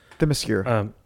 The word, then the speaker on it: Themyscira: Themyscira. – Themyscira.